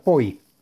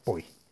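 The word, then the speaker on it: Poi (פוי): Y es el santo nombre de las letras Pei Vav Yud, se dice y se pronuncia Poi.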